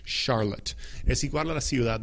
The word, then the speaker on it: Charlotte: Se pronuncia de la misma manera que Chicago. Chicago. Chicago. Es con C-H. C-H. Pero suena como si fuera SH, ¿me entienden? Charlotte, Chicago, ¿ok? Pero Charlotte se deletrea C-H-A-R-L-O-T-T-E. C-H-A-R-L-O-T-T-E.